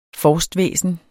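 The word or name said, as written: FORSTVÆSNET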